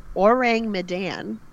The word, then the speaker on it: Orang Medan: So, I'm saying this right, Orang Medan. – Yep.